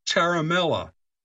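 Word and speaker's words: Ciaramella: But it's pronounced like Charlie Charamella.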